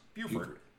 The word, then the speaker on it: Beaufort: Spelled Beaufort, but pronounced Beaufort.